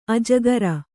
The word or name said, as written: ಅಜ್ಜುಗಾರ